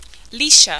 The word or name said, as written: Lisha